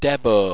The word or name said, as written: debbo